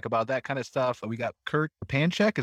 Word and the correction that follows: Repincheck: Is that, am I pronouncing that right, Kurt? – No, you got the Kurt part. – All right. How do I say it then? – Repincheck. – Okay. – Repincheck. – All right. Got it. I won't mess it up again.